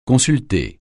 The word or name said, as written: consulter